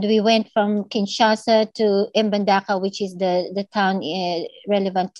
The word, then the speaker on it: Mbandaka: Mbandaka is the town.